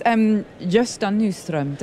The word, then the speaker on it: Gösta Nyström: So this is Gösta Nyström.